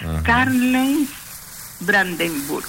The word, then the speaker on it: Karl-Heinz Brandenburg: Fue inventado por un ingeniero alemán, bueno, cómo se pronuncia, no sé, pero yo, como dice acá, Karl-Heinz Brandenburg.